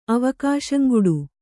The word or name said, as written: ಅವಕಾಶಂಗುಡು